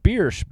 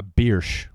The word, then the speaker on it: Biersch: Gordon Biersch, B-I-E-R-S-C-H. How the fuck would you pronounce that? – Biersch. – All right, fine. Well, it's Biersch. – No, it's Biersch. He's right.